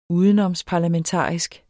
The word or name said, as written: udenomsparlamentarisk